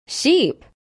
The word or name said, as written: sheep